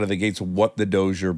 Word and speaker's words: Dozier: Is it the Dozier? – Dozier.